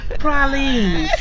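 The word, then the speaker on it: praline: Did I say that right? – I don't think so. Close. Close with no cigar. – Sure how to say it. – Pralines. Buy a Lex and get some pralines.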